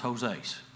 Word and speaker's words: Joses: This is to be the same Barnabas that's referred to later on, but just recognize his first name was Joses, is how that's pronounced there.